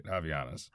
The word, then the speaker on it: Havaianas: It's like, all right, yeah, well, I've tried the you know, kind of rubbery, kind of more, I guess, Havaianas? How are we saying that? – I'm pretty sure I got that pronunciation right. Havaianas. – Havaianas?